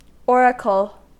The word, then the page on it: oracle: American English